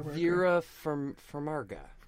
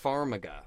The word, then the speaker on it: Farmiga: How do you say her name? Vera Farmiga? – Vera Farmiga. Farmiga.